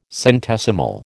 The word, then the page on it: centesimal: American English